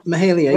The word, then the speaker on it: Mahalia: Is I pronounce that right? Mahalia, the gospel singer? – Mahalia, right.